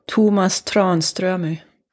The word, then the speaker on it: Tomas Tranströmer: And how is his name pronounced? – Tomas Tranströmer.